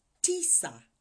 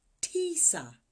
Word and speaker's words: tisa: Nine is pronounced Tisa. Tisa.